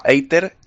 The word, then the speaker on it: Eitr: Sí, y yo añadiré uno que no ha salido todavía, que se llama Eiter, E-I-T-R, se escribe.